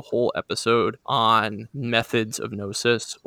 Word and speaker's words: gnosis: Gnosis. – Gnosis. It's like Gnocchi.